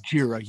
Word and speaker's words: Gojira: And so kind of the origins of Godzilla was obviously it's not the same as Kong, but he was Gojira. You say it correctly.